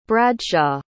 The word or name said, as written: bradshaw